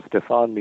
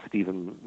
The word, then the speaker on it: Stefan: Well, if you pronounce it as the family pronounces it, it's Stefan Michalak, but everybody says it's Stephen Michalak.